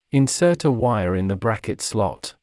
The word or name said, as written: insert a wire in the bracket slot